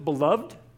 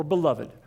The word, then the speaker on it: beloved: Now, there's two ways to pronounce it, beloved or beloved. Both are correct, so let's not get that problem out of the way right now.